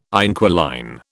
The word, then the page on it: inquiline: British English